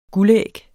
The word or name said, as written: guldæg